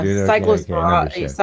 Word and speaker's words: cyclosporiasis: The outbreak of cyclosporiasis. How do you pronounce that, Jennifer? – I think you're doing it. Yeah, cyclosporiasis. Okay, it's explosive lettuce.